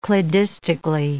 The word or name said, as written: cladistically